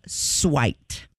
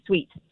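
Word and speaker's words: suite: It's a different kind of sweet spelled a different way. Sweet. S-U-I-T-E, S-W-E-E-T.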